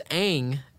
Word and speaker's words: Aang: Yeah, they literally mispronounced the main character. It's Aang, Aang is in...